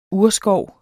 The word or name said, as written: urskov